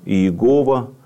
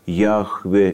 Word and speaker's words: Яхве: это Иегова, а, скажем, в современной библеистике более принято произносить это имя как Яхве или Ягве.